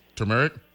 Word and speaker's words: turmeric: So so I learned the word turmeric. That's how you pronounce it, right? Turmeric? – Turmeric. – Okay.